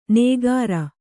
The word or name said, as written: ನೇಗಾರ